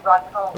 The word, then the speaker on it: rachum: The rachum. Rachum, yeah. Yeah, thank you. The word merciful is rachum, R-A-C-H-U-M. – Can you spell that one for loving kindness? – C-H-E-S-E-D.